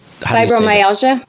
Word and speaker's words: fibromyalgia: but it's the fibromyalgia. – Fibromyalgia.